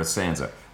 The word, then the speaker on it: Sansa: We're going to get into sort of the details, but why the hell doesn't Jon Snow know about Stanza yet? – Stanza? – Or Sansa.